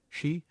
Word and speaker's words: Xi: And now we have not only fast food all across China, but again, pronunciation, Xi, S-H-I? I got that right? – Mm-hmm.